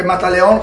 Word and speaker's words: Mataleon: Comunque Mataleon con l'accento sulla O. È importante perché così vi trovano subito.